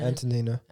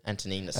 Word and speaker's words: Antonina: Antonina. – Antonina, sorry.